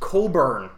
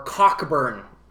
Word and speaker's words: Cockburn: Bruce Colburn. – Yes. – Or Cockburn, because that's how it's spelled.